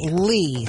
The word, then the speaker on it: Lee: My middle name is Lee. – I knew that. – But it's spelled Chrissy Lee. – L-E-E, not L-E-I-G-H.